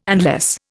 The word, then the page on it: unless: Indian